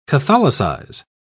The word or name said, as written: catholicize